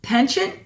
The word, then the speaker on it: penchant: Her own artistic, I'm like thinking, how do I say this word? Penchant? Is that how you say it? – That's correct.